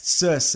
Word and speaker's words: Circe: Circe. It's C-I-R-C-E.